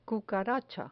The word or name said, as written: cucaracha